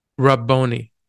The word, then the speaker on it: Rabboni: She turned and said to him in Aramaic, Rabboni. Did I say that right? – Yeah.